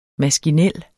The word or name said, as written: maskinel